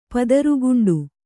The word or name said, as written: ಪದರುಗುಂಡು